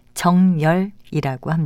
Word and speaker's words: 정열: 그리고 발음할 때는 두 번째 음절에 니은을 첨가해서 정열이라고 합니다.